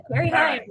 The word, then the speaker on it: Carrie Higham: Carrie Higham.